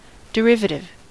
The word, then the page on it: derivative: American English